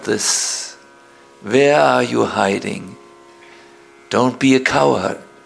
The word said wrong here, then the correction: coward: How do you say in English? Coward. Yes, not cowherd. Coward.